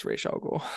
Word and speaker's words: Ra's al Ghul: What if I told you that Denny O'Neill, the creator of Ra's al Ghul, says that it's Ra's al Ghul? – Then I would say that it's probably supposed to be pronounced Ra's al Ghul.